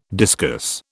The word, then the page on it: discus: British English